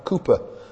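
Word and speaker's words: Cowper: We sang a hymn this morning by William, well, some people say Cooper, I think is the correct pronunciation.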